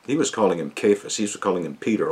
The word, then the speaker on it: Cephas: You shall be called Cephas, or you might say Cephas. I like to use the Aramaic pronunciation.